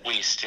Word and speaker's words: Wiest: Wiest, yeah.